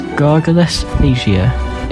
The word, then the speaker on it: gargalesthesia: This week's word is gargalesthesia. It is spelt G-A-R-G-A-L-E-S-T-H-E-S-I-A.